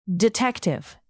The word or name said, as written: detective